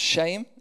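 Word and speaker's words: shame (שֵׁם): And the word for name, which is pronounced shame in this passage, it's repeated again and again and again in this passage.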